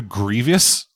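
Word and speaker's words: Grevioux: I think the main reason this thing exists, though, dead, is because of the other Scream person involved, Kevin Gravois. I don't know if I'm saying his name correctly. – I watched an interview with him, and apparently it's pronounced closer to Grievous.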